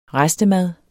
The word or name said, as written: restemad